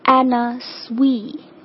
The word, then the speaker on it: Anna Sui: 下一个牌子呢，其实是来自于咱们华裔的一个设计师哈，中文应该是叫安娜苏对吧，这个牌子它的正确发音应该是 Anna Sui。 OK， Anna Sui 而不是 Anna Sui， Anna Sui 是正确的发音。